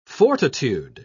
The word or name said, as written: fortitude